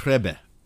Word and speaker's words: Rebbe: How do you pronounce it? – Oh, Rebbe.